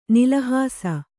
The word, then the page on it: ನಿಲಹಾಸ: nilahāsa